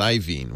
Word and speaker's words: Iovine: I'm told now it's pronounced Iovine, which doesn't make any. It's spelled Iovine.